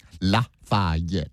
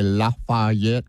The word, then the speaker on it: Lafayette: People get mad if you say Lafayette because it's Lafayette, but you know, one of the greatest Cajuns of all time, Justin Wilson, used to say Lafayette. And so I say it like he used to because I grew up listening to his records.